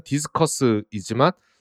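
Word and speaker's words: Discourse: 이 디스커스는 발음은 영어로 토론을 의미하는 디스커스이지만 스펠링은 D-I-S-C-O-U-R-S 이렇게 이제 외국 사람들이 많이 하는 이제 발음 비슷하게 해서 장난치듯이 하는 그런 식의 네이밍이고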